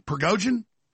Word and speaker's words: Prigozhin: Prigozhin, how do you pronounce his name? Prigozhin? Third time I got it right, I think.